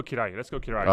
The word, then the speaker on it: Kiraly: K-R-A-L-J. Google, he's a cool guy, man. But let's go Kiraly. – I appreciate that. – Yeah, Gábor Kiraly. – How do you spell it? – Kiraly. K-I-R-A-L-Y.